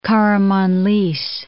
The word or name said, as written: Karamanlis